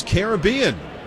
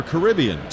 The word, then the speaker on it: Caribbean: And it is Caribbean, or Caribbean, tomato, tomato.